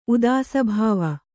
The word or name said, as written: ಉದಾಸಭಾವ